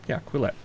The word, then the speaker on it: Quillette: Quillette. Yeah. I'm never really sure how to pronounce that, but it's just double L. So Quillette.